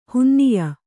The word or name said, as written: ಹುನ್ನಿಯ